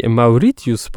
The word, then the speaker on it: Mauritius: Mauritius po angielsku czyta się jako Mauritius, a więc no tutaj w wolnym tłumaczeniu.